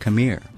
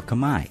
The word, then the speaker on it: Khmer: That's Boomer rapping mainly in Cambodia's national language, Khmer, or Khmer as Cambodians pronounce it.